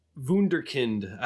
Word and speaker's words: Wunderkind: And he was like, the term you sometimes see is Wunderkind.